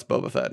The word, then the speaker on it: Boba Fett: No, it's Boba Fett.